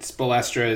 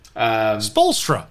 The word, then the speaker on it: Spoelstra: Spoelstra.